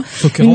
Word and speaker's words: Sokeranto: je ne sais pas si je vais le prononcer correctement, c'est le Sokeranto, c'est ça? – Oui, c'est bien, Sokeranto.